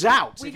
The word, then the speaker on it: zout: And that's zout. – How is that pronounced then? – Zout. Yes, yes, zout.